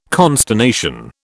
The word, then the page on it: consternation: British English